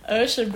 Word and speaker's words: Usha: U-S-H-A. Are you saying like Usha, baby? – Yeah.